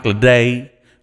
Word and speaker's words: Ledette: Yo, and our good friend Mike Ledette, or he says Mike Ledette with a silent T. This is the U.S. We don't silence a T.